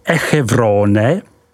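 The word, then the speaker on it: Échevronne: Côte du Beaune, vyslovko du Beaune, se rozkládá od hranic obce Echevrone, vyslov Echevron, po Chagny, vyslovuj Šaní.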